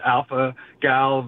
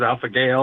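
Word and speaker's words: alpha-gal: My first time I called in was to correct you when you were talking about alpha gal versus alpha gale.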